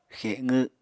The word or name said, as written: xeqw